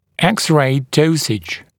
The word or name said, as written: X-ray dosage